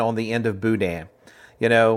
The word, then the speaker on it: boudin: Both of you don't say boudin with a hard N at the end. – No, it's Dan. Like, you know, we don't correct you because we're Cajuns. You're endeared to us because you mispronounce it. We love that. – Well, we have boudin up here in Iowa now. – That was very close. Boudin. Yeah, boudin. It's an eh.